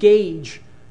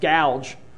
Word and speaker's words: Gouge: His name is Dr. I was told by folks in Greenville that you should pronounce it Gage, Dr. Gage, even though I'm looking at it, it looks like it should be pronounced gouge.